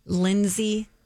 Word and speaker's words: Lynsi: Lindsay Snyder, spelling L-Y-N-S-I, Lindsay. – Say it again. – Lindsay Snyder. – Spell it. – L-Y-N-S-I.